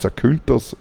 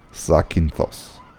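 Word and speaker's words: Sakynthos (Ζάκυνθος): Daher die Insel Zakynthos heißt nicht Zakynthos, sondern Sakynthos.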